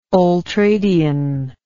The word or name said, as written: ultradian